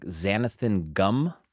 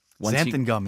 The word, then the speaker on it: xanthan gum: She sent a list of all the animals, A to Z, with animal names, and then some of the random things like xanthan gum. – Xanthan gum. – Okay, I was saying that wrong.